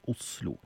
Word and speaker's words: Oslo: Det snart bare vestkantfolk over 50 år som sier Oslo. En ny studie av Oslo, eller Oslofolks språkvaner, viser at Oslo i ferd med å ta helt over for uttalen med tynn S.